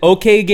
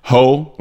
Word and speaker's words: Hokage: So he pronounced, he said, ho, like my girl. Yeah. Duh. That's funny. Ka, like the noise of crow mates. Ka. Mm-hmm. Ho, ka. Gay, pronounced like gay. Hokage.